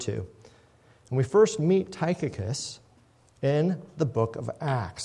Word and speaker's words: Tychicus: Tychicus, or maybe some of you know him as Tychicus, but I have it on very good authority from a professor of Greek that his name is actually pronounced Tychicus, is a name we actually see five times in the New Testament.